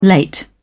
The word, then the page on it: late: British English